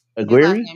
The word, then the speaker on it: Aguirre: Nick uh tried to come out Aguilar. Is that his right name? How you say his name? Aguirre. Nick Aguirre.